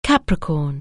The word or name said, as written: Capricorn